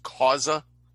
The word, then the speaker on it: Xhosa: That's the X-H-O. – Yes. – Yes. Khoza is X-H-O-S-A is how, and it's pronounced Khoza, like yes. – A K. – You pronounce it like a K.